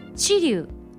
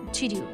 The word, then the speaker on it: 知立: 一般的には前側を強く発音していますが今ねすごくそこのところが太文字になっていたのであの「岡」と「あん」と「ち」と「に」がねわざと岡崎安城知立西尾って読んだ見たんですが地元では平坦に読んでいるんです。